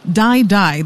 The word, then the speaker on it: Dai Dai: What is kind of unfortunate, though, at least to me, is that the song is called Die Die. That's spelled D-A-I-D-A-I.